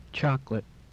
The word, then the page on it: chocolate: English